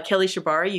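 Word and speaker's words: Kelly Shibari: So if you go to Kelly Shabari, you can Google me.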